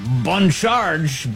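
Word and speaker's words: Bon Charge: This half hour, we thank the fine people at Bun Charge. Really nailing that slightly French pronunciation.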